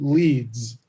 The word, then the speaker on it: Ledes: My father used to say anything but lead ass. it's a long story. But he pronounces it Leeds, like the city.